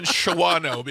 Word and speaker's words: Shawano: I didn't know that, but they live in Shawano because sorry, I'm a child of phonics. – No, it's pronounced Shawano.